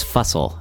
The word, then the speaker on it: Fussell: It's Fussell, actually. Stakes, of course, is spelled S-T-E-A-K-S.